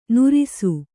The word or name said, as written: ನುರಿಸು